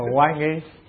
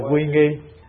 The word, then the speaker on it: quy nghi: Chữ quy này đọc theo ngôn ngữ giọng Bắc, giọng Nam thì ta đọc là quan nghi, giọng Bắc là quy nghi.